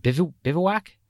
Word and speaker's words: bivouac: There's a campground that's open May 27 or November 15, and it's how do I pronounce this word, Jim? Bivouac? – You got it.